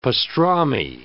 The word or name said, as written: pastromi